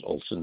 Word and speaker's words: Olsson: Last name is Olson. That's spelled O-L-S-S-O-N.